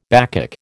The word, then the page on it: Bacchic: American English